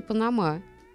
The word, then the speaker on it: Панама: Ну, я, кстати, где-то а, где-то слышала, и, по-моему, даже в словаре ударений я проверяла, и оказалось, что можно говорить и Панама.